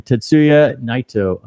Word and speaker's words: Tetsuya Naito: Tetsuya Naito of Los Ingobernables de Japón.